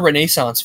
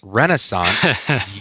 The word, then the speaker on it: Renaissance: How was your Renaissance fair? – It's pronounced Renaissance, you heathen from New Jersey, and it was a lot of fun.